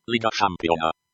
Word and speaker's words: Liga Šampiona: So the pronunciation in Croatian. – Liga Šampiona. Liga Šampiona.